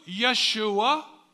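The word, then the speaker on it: Yeshua (ישוע): The name Jesus in Hebrew, Yeshua. There's a whole book called Joshua that would have been pronounced Yeshua.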